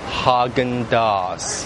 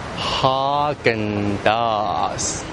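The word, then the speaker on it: Häagen-Dazs: 叫做 Hagen Dazs, Hagen Dazs, Hagen Dazs.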